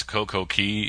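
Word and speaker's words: Coco Cay: And I haven't had the heart to tell you that I think it's Coco Key, even though it's spelled C-A-Y.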